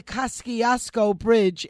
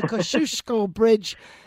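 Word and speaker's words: Kościuszko: it's not the Kosciuszko Bridge, it's the Kosciuszko Bridge, and you got to give it the Polish pronunciation that it's due.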